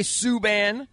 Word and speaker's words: P.K. Subban: P.K. Subban. Is that how you say his name, Cakes? – Yes, you got P.K. – it. – P.K. Subban.